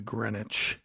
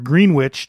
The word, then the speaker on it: Greenwich: This one's called Greenwich, or Greenwich, depending on how you pronounce it.